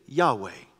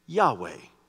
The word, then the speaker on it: Yahweh: In the Hebrew language, the name for God only has really four consonants. If we transliterated it, it would be YWYH. Later on, they added a few vowels to it, made it easier to pronounce. We just would say Yahweh.